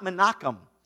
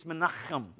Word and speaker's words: Menachem: Now, it's not Menachem. It's Menachem. You have to say it like that or you didn't say it right.